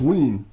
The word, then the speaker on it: Wearne: No, it ain't. It's Wearne. W-E-A-R-N-E. Keith Wearne. – Pronounced Ween.